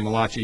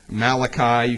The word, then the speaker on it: Malachi: You can pronounce his name, Malachi. You can also pronounce it Malachi. You can also pronounce it, I think David Hawking pronounced it, Malachi. And that's correct, too.